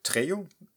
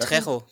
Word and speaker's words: Trejo: Ja, hier Danny äh, Trejo. – Oder Danny Trejo, genau. Trejo, Trejo, genau. Ja, Entschuldigung, Spanisch, dann wird, wird auch Trejo ausgesprochen.